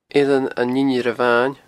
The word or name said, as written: Eilean an Inbhir Ebhain